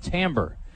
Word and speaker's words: timbre: I said timber instead of timbre, which I've been mispronouncing it most of my life, but it's basically the richness of a sound.